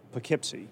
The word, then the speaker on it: Poughkeepsie: P-O-U-G-H-K-E-E-P-S-I-E. – Poughkeepsie? – No. – No, Poughkeepsie? – No. – Okay. – How's the New York one pronounced? – Poughkeepsie. – Yeah.